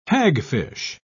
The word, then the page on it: hagfish: British English